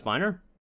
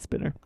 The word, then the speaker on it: Spiner: Isn't it Brent Spiner? – Spiner, yeah, sorry. I always pronounce it Spinner.